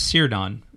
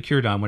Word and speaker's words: Círdan: Which is pronounced Círdan, right? You might look at that and want to pronounce it as Círdan, right? Mm-hmm. But it's actually Círdan. Whenever you see a C by itself like that, it's pronounced as a K sound, right?